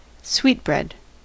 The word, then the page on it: sweetbread: American English